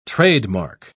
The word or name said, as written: trademark